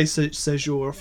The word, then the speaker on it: Séjour: Now say it in New Orleans. – Victor Séjour. Okay. – It's so funny because you did say it in New Orleans. You didn't say it like an American accent. – I didn't. So we can just say Séjour from now on.